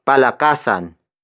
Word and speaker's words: Palakasan: This lesson is brought to you by Palakasan. Palakasan. Palakasan. Palakasan is spelled as P-A-L-A-K-A-S-A-N.